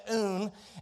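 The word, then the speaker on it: oon (οὖν): The word therefore comes from the Greek word oon. That's how you say it, oon.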